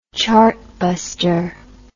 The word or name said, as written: chartbuster